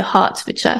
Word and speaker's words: Hertfordshire: Now, where you're at in England, Hertfordshire, is that right? How do you say that? – Yeah. – Hertfordshire? – Yeah, you say it like an A, so Hertfordshire. – Hertfordshire.